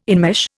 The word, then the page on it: enmesh: Indian